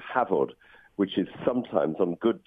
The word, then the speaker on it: Hafod: It's spelled H-A-F-O-D, but F in Welsh is pronounced V, so it's pronounced Havod, Havod.